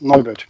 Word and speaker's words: Neubert: Yes, Neubert.